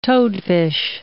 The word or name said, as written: toadfish